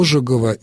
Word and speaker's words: Ожегов: Ну да, у Ожегова, не Ожегова, Сергей Константинович, Ожегов, правильное ударение.